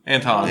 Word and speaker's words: Anton: That's what he was too weird to be around Antoine LaVey. Right, that's how you say his name? – Anton.